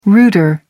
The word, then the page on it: router: American English